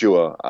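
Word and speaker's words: Tchouá: it's Chua, um, T C H O U A, six six six six and a half man child.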